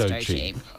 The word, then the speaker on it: Doechii: Yes, it's Doechii. – I was putting an extra syllable in there because I'm an old person and don't know how to pronounce young people.